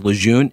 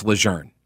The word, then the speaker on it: Lejeune: It's Lejeune now. Well, I mean, I guess it always has been, but we've all been pronouncing it as Lejeune incorrectly. The family says it's Lejeune, and so there's this big push for us to pronounce it correctly.